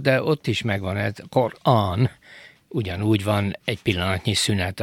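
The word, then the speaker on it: Qur'an: Megfigyelte, hogy a Korán kiejtésekor is szünetet tartanak az R és az A között,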